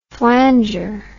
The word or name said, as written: flanger